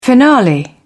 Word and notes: finale: British English

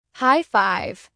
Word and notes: high five: American English

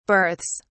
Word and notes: berths: British English

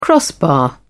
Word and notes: crossbar: British English

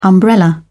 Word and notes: umbrella: British English

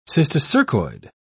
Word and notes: cysticercoid: British English

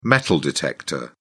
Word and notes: metal detector: British English

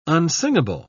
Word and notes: unsingable: British English

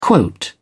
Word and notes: quote: British English

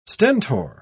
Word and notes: stentor: British English

